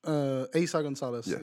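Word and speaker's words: Eiza González: Aiza Gonzalez, yep.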